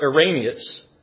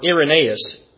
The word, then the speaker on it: Irenaeus: And so the external evidence includes the comments of an early church leader by the name of Irenaeus, or Irenaeus, different pronunciations.